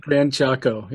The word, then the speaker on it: Gran Chaco: Gran Cacho, does that? – Gran Chaco, yeah.